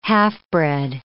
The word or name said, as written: half-bred